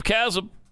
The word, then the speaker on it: chasm: There's a chasm between it instead of chasm. Because John Kerry corrected our mispronunciation of the word and let us know it was chasm.